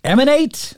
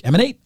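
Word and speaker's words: Eman8: This week for me, I believe his name is Emanate. I believe is the way that you pronounce it. It's E-M-A-N-8, but I stumbled across his music. He's got a song called I-O-U.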